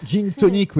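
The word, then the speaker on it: Jean Tonic: Alors, Gin Tonic, oui, parce que ça s'écrit J-E-A-N, comme Jean, mais c'est Gin Tonic.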